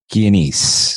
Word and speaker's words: Chianese: Dominic Chianese.